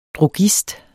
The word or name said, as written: drogist